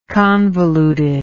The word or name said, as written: convoluted